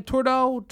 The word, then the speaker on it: Trudeau: Trudeau.